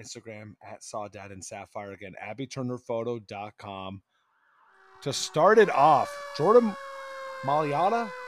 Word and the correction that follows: Mailata: I feel like I always pronounce his last name. Malata. You're muted, but. – Oh, I've been trying to. I've been like sitting here. I'm like, maybe I'm going nuts. But yeah, it's Jordan Mailata.